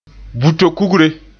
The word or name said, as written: buto kugre